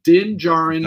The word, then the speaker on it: Din Djarin: With assistance from Grogu, Din Djarin, which I'm now seeing is spelled D-I-N-D-J, the D is silent, there's two D's. He's double D. Din Djarin.